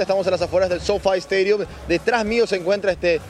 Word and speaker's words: SoFi Stadium: El estadio Sofá. – Sofi Stadium. – Sofi Stadium. – Sofi se escribe. – Derógape. – Pero Sofi se lee.